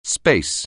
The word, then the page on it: space: anglais